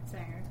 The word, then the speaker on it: Sanger: Margaret Sanger? Sanger? – Sanger. – Sanger? – Mm-hmm.